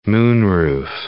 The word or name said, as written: moonroof